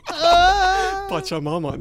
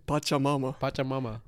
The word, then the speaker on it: Pachamama: Pachamama. That's how we call Mother Nature in Quechuan. – How do you say it? – Pachamama.